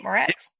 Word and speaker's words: Moretz: Uh, Chloe Grace Moretz, did I say it right? – Moretz, uh, was great.